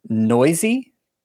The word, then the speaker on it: Neuse: Sheldon Noisy. – Yep, that is how you say his name. I remember hearing it and almost like, what? – N-E-U-S-E, Noisy.